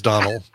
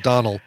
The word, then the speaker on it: Domnall: Domnall. But I think it's pronounced Donald, it's definitely pronounced Donald, but it's spelled Domnall.